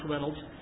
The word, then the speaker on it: Raynolds: And the man who led them was a man by the name of John Reynolds, R-A-Y-N-O-L-D-S. It's spelled a little bit funny, but it's pronounced Reynolds.